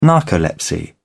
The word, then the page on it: narcolepsy: British English